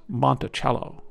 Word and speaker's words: Monticello: We were told by the guide that the pronunciation was Monticello, like the musical instrument.